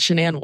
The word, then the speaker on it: Shanann: Also, my current documentary fixation is the one about Chris and Shanann Watts. – Is that how you pronounce her name? – Yeah. – Shanann. – In the documentary, because she says her name, she's like, hey, I'm Shanann.